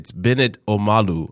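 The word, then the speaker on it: Bennet Omalu: It's Bennett Omalu, I believe, is how you pronounce that. That is absolutely correct.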